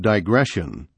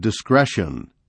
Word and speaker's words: digression: Sometimes congregations pronounce the line, "Let no spirit of digression," correct, as "let no spirit of discretion," incorrect.